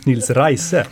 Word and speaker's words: Nils Riese: Det är som i Reinfeldt. – Som jag mycket riktigt lärde mig i tyskan, så ska man uttala ditt namn Nils Riese.